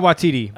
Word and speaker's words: Waititi: It is Waititi. – Taika Waititi.